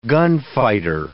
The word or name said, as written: gunfighter